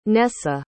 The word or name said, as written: Nesa